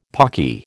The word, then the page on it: pawky: American English